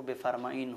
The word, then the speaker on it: بفرمائید: تو ایسا نہیں ہے کہ یہ کوئی دوسرا لفظ ہے, بلکہ اس کا تحریری وجود بفرمائید ہے, مگر تلفظ اور ادائیگی کے اعتبار سے وہی لفظ بفرمائین ہو جاتا ہے.